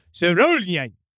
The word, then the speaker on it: Sørjuleaften: It was called Sørjuleaften. That definitely was what it was called. – Yeah. – That's how you pronounce it.